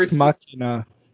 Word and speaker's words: Ex Machina: You never Ex Machina. – Machina. Oh, yes. – I was like, Ex Monica. What's that movie about? – Who's that? – That's just that not really pronouncing words correctly.